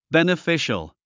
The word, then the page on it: beneficial: bènəfíʃəl; ベネフィシャル